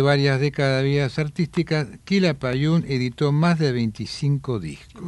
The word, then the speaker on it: Quilapayún: Buscaron un nombre indígena que tuviera acento en la última sílaba y se les ocurrió Quilapayún, que en araucano significa tres barbas.